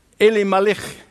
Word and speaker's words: Elimelech: Naomi and her husband, pronounced Elimelech in Hebrew. Eli, you remember? Eli, Eli, lema sabachthani? When Jesus said, my God, the name of God, Eli. Melech means king. God is my king.